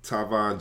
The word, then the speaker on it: Tyvon: You find me on Facebook using my real name, Tyvon Gentry. Spell that T-Y-V-O-N, last name Gentry, G-E-N-T-R-Y.